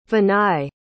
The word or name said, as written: vinay